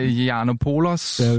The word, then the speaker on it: Yiannopoulos: Ja, Yiannopoulos.